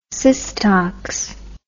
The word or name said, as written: systox